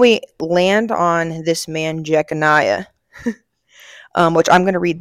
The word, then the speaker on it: Jeconiah: Okay, I was going to say it right. It's Jeconiah.